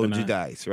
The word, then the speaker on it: Giudice: Or Giudice, depending on which side of the coin you're on as far as the pronunciation. But it's Giudice, easier for me and probably so many other people to just say Giudice, so whatever.